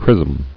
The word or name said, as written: Chrismal